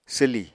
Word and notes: swlì: apatani

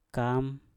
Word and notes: kam: Mpur

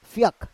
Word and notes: fyak: Roon

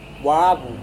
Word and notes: wavu: Serewen

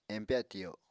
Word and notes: empya tiyo: Apatani

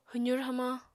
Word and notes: hwzur hamañ: apatani

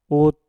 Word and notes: ut: mpur

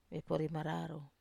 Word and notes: vepori mararau: Wamesa

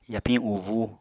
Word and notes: yapiñ ubúu: Apatani

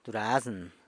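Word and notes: durazn: San Lucas Quiaviní Zapotec

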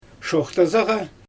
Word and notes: ʃoxtazaɣa: Xyzyl

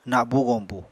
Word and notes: nabu-goñbu: Apatani

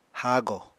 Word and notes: hago: Apatani

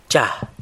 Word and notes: cha: San Lucas Quiaviní

